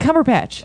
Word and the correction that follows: Cumberbatch: No, you're saying it wrong. You said patch. It's batch. – Benedict Cumberbatch.